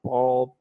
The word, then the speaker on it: bald: Your ball head? I don't want to know about that. – My bald head. – Your bald head. – Yeah, my bald head. – Sure you pronounced the D there.